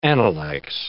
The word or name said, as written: analects